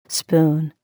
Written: spoon

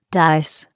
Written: dice

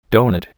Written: doughnut

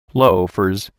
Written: loafers

